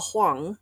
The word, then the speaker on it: Huang: Huang, which I'm glad I checked because she is pretty emphatic about don't pronounce it to rhyme with bang, rhymes with Wong, wrong.